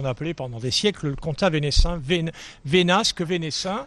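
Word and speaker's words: Comtat Venaissin: comment on prononce ? – C'est le Conta Venaissin.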